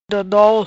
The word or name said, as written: Dodol